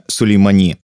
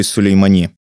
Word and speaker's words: Сулеймани: Как убили Сулеймани? По крайней мере, я увидел, что на персидском у него ударение ставится на последний слог.